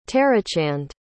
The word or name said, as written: tarachand